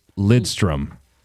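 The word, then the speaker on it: Lidström: There's a mystery N that people like to add in. It's Lidstrom. But there's a lot of people, I mean, Tom, going back in the day, Lindstrom.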